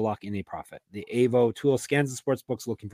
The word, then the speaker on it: AVO: It's spelled AVO, pronounced AVO.